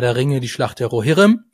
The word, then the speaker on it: Rohirrim: Die Betonung auf dem O, ob es das erste ist, das betont wird, oder das zweite. – Also Rohirrim.